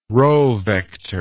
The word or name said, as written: row vector